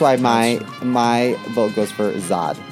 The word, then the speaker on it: Zod: It's pronounced Zod.